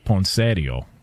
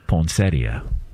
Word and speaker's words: poinsettia: Well, the in-laws would call it poinsettio. – Oh gosh. – Okay. What up? You know what I'm saying? Can we get the proper pronunciation on this? – It does say poinsettia online.